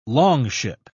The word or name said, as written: longship